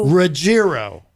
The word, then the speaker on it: Ruggiero: I always think it's a soft G. I always say Ruggiero, but it's Ruggiero.